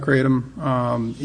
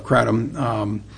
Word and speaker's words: kratom: And so when we've talked about or we've heard people talking about the legalization the regulation of kratom. – Is that how you say it? – It goes either way. I get told by staff that it's both ways.